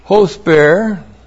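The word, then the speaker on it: hosper (ὥσπερ): First of all, hosper. H long O S P E R. Hosper.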